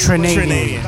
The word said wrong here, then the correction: Trinidadian: Is that how you say it? Trinidadian, is it? Trinidadian? – I don't know. – I think it's Trinidadian.